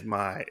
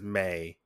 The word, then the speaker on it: Mai: I believe it's May. Is it May or Mai? I can't remember. – I think it's Mai because M-I-I. – It's Mai. It's Mai. But in the dub, they pronounce it as May.